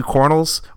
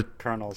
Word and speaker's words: colonel: Metro Exodus, the first DLC pack for that, the two Cornels, or Connels. I never... – Kernels. – Kernels. It doesn't look like Kernels, so I never say it like Kernels.